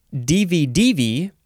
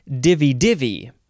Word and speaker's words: divi-divi: The first word in this episode is, you can pronounce it divi-divi, the third syllable is emphasized, or divi-divi. Divi-divi, divi-divi. It is just D-I-V-I hyphen D-I-V-I.